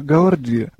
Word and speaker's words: ГААОРДИ: Скажите, пожалуйста, я неправильно произнесла ударение, принято ГААОРДИ, говорит, да? – Ну, вообще, насколько я слышал, да, ГААОРДИ. Но люди говорят по-разному. То есть, насколько я понимаю, это разрешено, и никто не против.